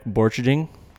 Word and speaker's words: Borcherding: I've got George Cummins from Salt River Outfitters, and I have Jack Borchiding. Did I say your name, last name right? – Yeah, that's perfect. Man, perfect. You did great.